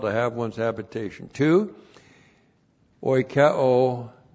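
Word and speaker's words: oikeo (οἰκέω): O-I-K-E, long O, oikeo.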